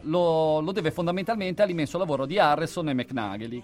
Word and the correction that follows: McConaughey: che sono, che io non lo so pronunciare, McConaughey. Però McNagley, prendetelo per buono.